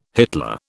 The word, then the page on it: Hitler: British English